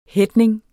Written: headning

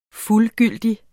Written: fuldgyldig

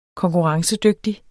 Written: konkurrencedygtig

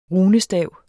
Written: runestav

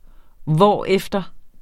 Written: hvorefter

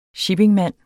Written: shippingmand

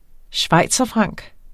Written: schweizerfranc

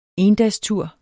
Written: endagstur

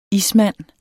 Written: ismand

